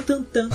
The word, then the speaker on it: Tintin: E Tintin a pronúncia tantan.